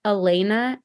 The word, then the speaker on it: Alana: Yeah, I'm on Instagram at Alana A L A N A K, which is K A Y, and then my last name, Beittel.